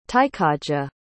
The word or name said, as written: tykaja